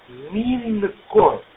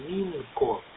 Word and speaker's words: Минко: Его имя Минко. Михаил Иван Николай Киловатт Ольга Минко. Ну, может быть, мне послышалось, что имя просто-напросто неправильно произносили. Его имя Минко.